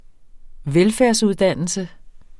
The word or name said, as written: velfærdsuddannelse